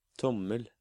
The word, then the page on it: tommel: Norwegian